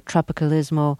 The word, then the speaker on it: Tropicalismo: Now, you were one of the people who took music in a slightly different direction, something that's been called Tropicália or Tropicalismo. Forgive my pronunciations. – It's perfect now.